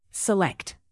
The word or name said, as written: select